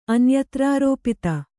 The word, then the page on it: ಅನ್ಯತ್ರಾರೋಪಿತ: anyatrārōpita